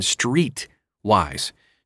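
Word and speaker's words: Streitwise: You can invest in non-traded REITs through crowdfunding platforms like Fundrise and Streetwise. Street with REIT in the middle spelled like REIT.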